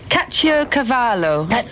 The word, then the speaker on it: Caciocavallo: Right, there's one cheese, and I can't really pronounce it terribly well, but I think it would be Caciocavallo.